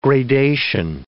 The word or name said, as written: gradation